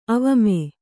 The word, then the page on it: ಅವಾಮ: avāma